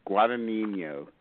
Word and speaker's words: Guadagnino: Guadagnino is how you say his last name.